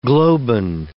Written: globin

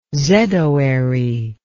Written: zedoary

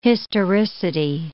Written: historicity

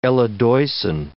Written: eledoisin

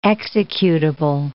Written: executable

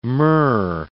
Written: murre

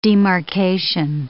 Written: demarcation

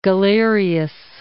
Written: Galerius

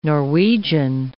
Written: Norwegian

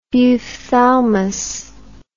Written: buphthalmos

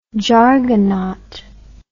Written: jargonaut

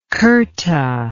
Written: khurta

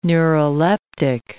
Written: neuroleptic

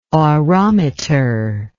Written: orometer

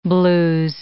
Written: blues

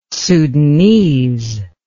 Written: sudanese

